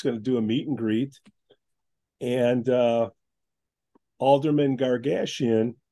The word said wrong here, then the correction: Gashgarian: Gashgarian, did I say that right? Because I don't want to screw up his first name. So Era Gashgarian. – Gashgarian. That's what I said.